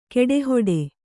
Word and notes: ಕಡೆಹುಟ್ಟು: kaḍehuṭṭu

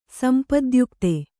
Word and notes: ಸಂಪದ್ಯುಕ್ತೆ: sampadyukte

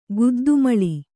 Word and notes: ಗುದ್ದುಮೊಳ: kannada; guddu moḷa

